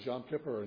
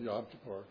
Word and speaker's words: Yom Kippur: Or, it's pronounced both ways, Yom Kippur and Yom Kippur.